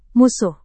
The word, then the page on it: musuh: Indonesian